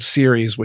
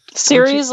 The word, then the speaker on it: Ceres: Like Ceres, like the goddess? – The goddess, yes.